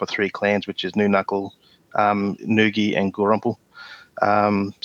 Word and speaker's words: Noonuccal: Did I say that right? – Oh, it's, it's, it's Noonuccal. – It's a, Noonuccal, sorry. I wasn't sure how to pronounce New it. Knuckle, yeah.